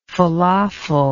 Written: felafel

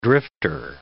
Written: drifter

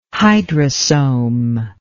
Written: hydrosome

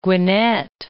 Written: Gwinnett

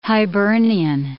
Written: Hibernian

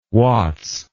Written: wats

